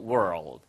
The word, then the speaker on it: world: In the world. World 발음을 조금 어려워하시는 분들이 계신데요. Word는 단어란 뜻이고요. World까지는 똑같아요. 근데 L을 한번 튕겨주세요. 혀 끝을 튕겨서 world. World 이런 식으로 발음하시면 이게 바로 세계란 뜻입니다.